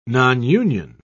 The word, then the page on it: nonunion: British English